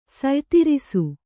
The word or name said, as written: ಸಯ್ತಿರಿಸು